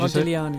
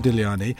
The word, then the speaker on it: Modigliani: You don't pronounce the G, I believe. Is that right? How would Modigliani. – You say it? Modigliani.